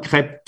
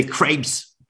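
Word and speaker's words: crêpe: It's not a cre, it's a cre, and a cre, it's cre, and it's one crepe, two crepe. Even if you write with S, you don't pronounce it. So you say, when you say crepes, you're a tourist.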